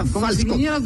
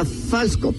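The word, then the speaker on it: Fältskog: Agneta, dime cómo se pronuncia. Falskork. Así. Ah, tú, dilo, con seguridad, Agneta Falskork. – de Estocolmo. Agneta Falskork. Perfecto.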